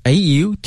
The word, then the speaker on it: autumn: autumn 这个 mn 特别容易犯错误，对，这 n 不发音啊，所以一定要给它写上，别不发音咱就给它忘了。